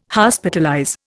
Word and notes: Hospitalize: Indian

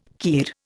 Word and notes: kir: Indian

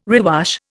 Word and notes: rewash: Indian

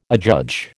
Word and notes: adjudge: American English

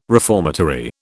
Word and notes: reformatory: British English

